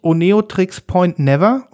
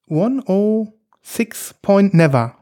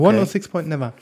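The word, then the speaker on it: Oneohtrix Point Never: Deswegen sagt man nicht Oneotrix Point Never oder ähnliches oder Uneotrix, sondern man sagt 1.06 Never. 1.0, nee, 1.0.6 Never.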